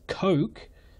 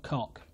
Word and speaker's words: Koch: On that note, I realise the American English pronunciation of the letters K O C H is Coke, being closer to the correct way of pronouncing his name, but I am English and I will, when I get to him, in Lindy Page levels of stubbornness, be calling him Robert Cock.